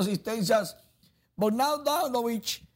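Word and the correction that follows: Bogdanović: Lo dije bien, Bogdanovich.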